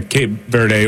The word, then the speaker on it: Cape Verde: For the old guys everywhere and for Cape Verde, or is that how you pronounce that, by the way? – Yeah, well, I think I got that right. – Yeah, Cape Verde, or in Portuguese, it's called.